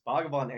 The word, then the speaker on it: Bhagavan: His name is Doc, and let me say his name right. Bhagavan? Is it Bhagavan? – Translates into Lord. – What? – It translates into Lord. – Yeah, his name is Doc Bhagavan Antle. It's Bhagavan Antle is his real name, and I think he goes by the name Doc.